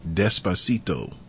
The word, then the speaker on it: Despacito: So the number one song on the Billboard charts is Despacito.